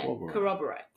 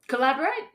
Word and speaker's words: corroborate: Yeah, corroborate. – Corroborate? Did I say it right? – Collaborate? No, corroborate.